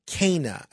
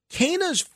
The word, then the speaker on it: CANA: Sir Patrick Stewart is joining CANA, I believe that's how it's pronounced, C-A-N-A, as a mission ambassador to help end the global trash crisis.